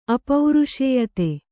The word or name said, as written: ಅಪೌರುಷೇಯತೆ